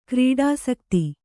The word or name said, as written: ಕ್ರೀಡಾಸಕ್ತ